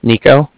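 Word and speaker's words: Niko: Yeah, so my name is Nico. That's N-I-K-O.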